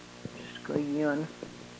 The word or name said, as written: skoyon